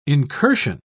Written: incursion

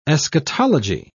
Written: eschatology

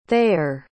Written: thayer

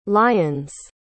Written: lyons

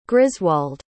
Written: griswold

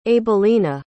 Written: abelina

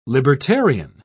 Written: libertarian

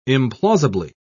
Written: implausibly

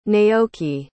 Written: naoki

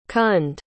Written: Kund

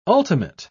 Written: ultimate

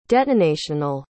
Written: detonational

